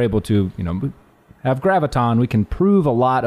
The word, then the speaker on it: graviton: So, I said it incorrectly, by the way. It's graviton.